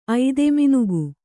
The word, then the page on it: ಐದೆಮಿನುಗು: kannada